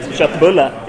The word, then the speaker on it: köttbulle: And then you can reply to the tweet saying that köttbulle in Swedish is pronounced köttbulle. It's not kottbulle or köttbulle or anything. It's köttbulle.